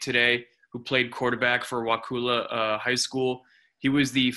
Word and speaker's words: Wakulla: Wakulla.